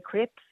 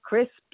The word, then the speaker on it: crisps: I say crips instead of crisps.